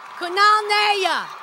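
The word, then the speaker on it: Kunal Nayyar: Oh, no. Nayar. Kunal Nayar.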